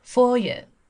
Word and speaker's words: foyer: Foyer, bathroom, standard suite, building, wing, landmark, block. – 好了，我们看一下，今天我要讲的是两个词哈，一个是叫做门厅。那么门厅这个词的发音要注意一下，它是一个法语词，叫做 foyer。 foyer，OK，